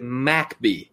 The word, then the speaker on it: McBee: And look, people, it's spelled McBee, but you have to pronounce it MacBee. There's no A in between the M and the C.